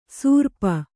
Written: ಸೂರ್ಪ

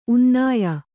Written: ಉನ್ನಾಯ

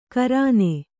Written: ಖುರಾನ್

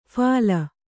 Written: ಫಲಿ

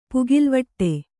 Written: ಪುಗಿಲ್ವಿಡು

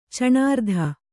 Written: ಚಣಾರ್ಧ

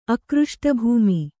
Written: ಅಕೃಷ್ಟಭೂಮಿ

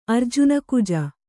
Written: ಅರ್ಜುನಕುಜ

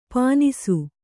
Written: ಪೀನಾಸಿ

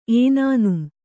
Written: ಏನಾನುಂ

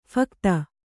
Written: ಫಕತ

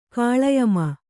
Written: ಗಾಳಿಯಮ್ಮ

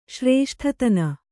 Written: ಶ್ರೇಷ್ಠತನ

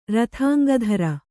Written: ರಥಾಂಗಧರ

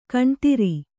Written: ಖೂನದೋರು

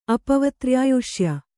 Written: ಅಪವತ್ರ್ಯಾಯುಷ್ಯ

